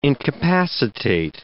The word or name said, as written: incapacitate